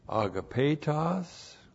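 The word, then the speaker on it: agapetos (ἀγαπητός): We got to the first word of verse 5, it's a verbal adjective, agapetos. Agapetos, A-G-A-P, long E-T-O-S.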